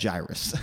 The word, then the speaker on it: Jairus: And so I think that like I used to say it like that, but then I always want to pronounce things right. Sometimes I don't, whatever. So I looked it up. It's actually just straight up Jairus.